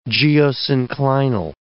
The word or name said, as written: geosynclinal